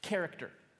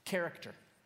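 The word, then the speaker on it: character (χαρακτήρ): It's pronounced like this, character, character.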